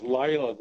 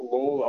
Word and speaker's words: Layla: Our best selling, and I think it's pronounced Layla, the song, not Lawler.